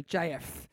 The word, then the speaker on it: Japheth: The P-H is a F, so Japheth.